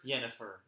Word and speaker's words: Yennefer: I've never played any of these Witcher games, but man, the name Yennefer is fun to say. – Yennefer? – Yennefer. It's like Jennifer, but with a Y.